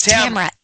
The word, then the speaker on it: Tamara: Yes, it's Tamara. T-A-M-A-R-A.